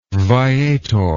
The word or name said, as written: viator